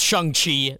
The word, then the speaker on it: Shang-Chi: So I could be pronouncing it wrong. It's pronounced Shang-Chi, the master of the Ten Rings, and he's the master of Kung Fu.